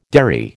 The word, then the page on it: derry: American English